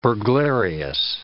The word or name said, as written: burglarious